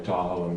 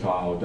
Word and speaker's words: Tahoe: Tahoe is derived from a Washoe phrase that describes Lake Tahoe, Da'a'aga. And we heard that, us white people heard it, Da'a'aga, Tahoe? Tahoe rhymes with Washoe?